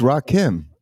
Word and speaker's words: Rakim: It's Rakim.